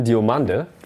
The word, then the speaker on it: Diomande: Jan Diomande, so wie er übrigens ausgesprochen wird, haben wir uns vorher extra nochmal schlau gemacht, ne? Hier ein Video bemüht, wo er es selber sagt. Diomande über links zu erwarten,